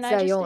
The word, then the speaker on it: Zajonc: okay, super English way of pronouncing this man's last name is going to be Zajonc, which I'm pretty sure there's a link to a YouTube video that pronounces it much more eloquently than Zajonc.